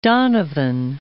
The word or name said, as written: Donovan